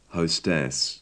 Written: hostess

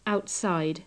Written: outside

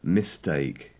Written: mistake

